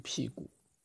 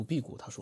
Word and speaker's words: 辟谷: 然后现在豪克他说，刚才听了最新一期科学边缘，彩云会说辟谷，开始我听成了屁股，他觉得很有意思。我说我也是，其实呢。有的时候我猛一眼看到这两个字呢，我也会读成屁股。